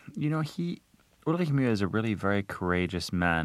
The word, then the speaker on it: Ulrich Mühe: I mean, the sound, which is in his last name, doesn't actually exist in the English language, so it's actually Ulrich Mühe.